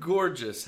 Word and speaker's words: gorgeous: The word gorgeous has two G's, a hard one and a soft one.